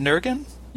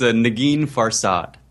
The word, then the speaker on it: Nagin Farsad: So how is it that – and by the way, am I pronouncing your leading lady's name right? Is it Nurgen? – You're not, but no one has ever pronounced it correctly. – Tell me. – It's Nagin Farsad.